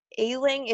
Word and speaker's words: Ealing: And I believe it's pronounced Ealing. It's E-A-L-I-N-G.